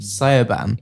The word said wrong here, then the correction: Siobhan: Yeah. Uh, what? Siobhan.